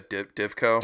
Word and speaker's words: Divco: It's like a Dineco or whatever milk truck. I'm probably saying that wrong. – Yeah, Divco. – Divco truck.